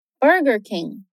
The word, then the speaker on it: Burger King: Burger King Burger King No inglês americano, que é o que você aprende aqui na Fluency, o R é sempre muito caipira. E em palavras onde ele aparece em duas sílabas diferentes, isso é ainda mais perceptível.